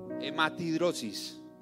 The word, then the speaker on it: hematidrosis: Hematidrosis. ¡Qué angustia! Ah, hematrosis, hematrosis. Mentiras, Marias. Gracias por desembalarme siempre. Yo lo pronuncié mal.